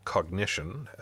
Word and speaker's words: Cognixion: So it's from this company called Cognition, which is spelled Cognixion.